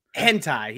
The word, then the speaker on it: Hentai: Or Friar's favorite high and tie, or however you pronounce it. – Hentai, yeah.